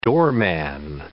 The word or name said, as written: doorman